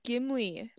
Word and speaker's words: Gimli: You completely butchered the main letter in that I said Ghibli, but it's Gimli.